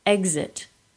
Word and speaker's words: exit: These words are pronounced as G plus Z, even though the stress in the word would not dictate it. Exit. Existential. Exile.